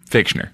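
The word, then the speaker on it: Fichtner: Am I saying that wrong? – Fictioner. – Okay, good. – Do you hear the difference? – Yes. Do it again. – Fictioner. – Fictioner. Thank you.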